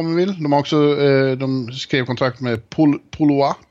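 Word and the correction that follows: Pouliot: Polo, nej? Polio. Eller ja, Polio. – Uttalar det ju svårt men det är Benoit Polio.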